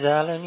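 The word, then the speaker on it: Alen: My name is Alan.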